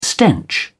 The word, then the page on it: stench: British English